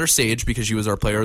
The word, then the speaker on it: Sage: S-A-G-E Romberg. We highlighted her. Make sure you say her name is Sage Romberg, not Shay Romberg, as Brian Tesh continually called her through the webcast.